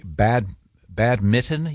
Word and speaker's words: badminton: No, we were talking about, I'm going to pronounce this incorrectly, badminton? You don't pronounce that N? – You do not.